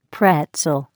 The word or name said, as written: pretzel